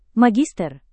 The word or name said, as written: magister